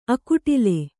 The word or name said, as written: ಅಕುಟಿಲ